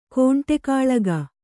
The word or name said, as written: ಕುಂಡಗೋಲಕ